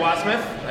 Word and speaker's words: Wasmuth: Our next guest here from the Hudson Valley Homebrewers, we have President Eric Wasmuth. I said it right, yeah? – That's right.